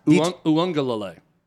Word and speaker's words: Uiagalelei: It's Uiagalelei. – DJ Uiagalelei. – Yeah, you're pronouncing every letter. You made the mistake of pronouncing every letter. Yeah. You're not supposed to.